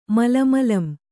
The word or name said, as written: ಮಲಮಲಂ